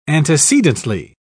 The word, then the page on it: antecedently: British English